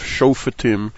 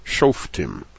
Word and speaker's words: Shofetim: I exaggerate the pronunciation always when I write it, Shofetim, because I grew up calling the Sedra Shoftim, as the first syllable would be Shof, and the second syllable would be Tim, and that's because I really didn't internalize and pay attention to the rules of Shvana and Shvanach.